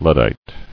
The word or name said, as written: Luddite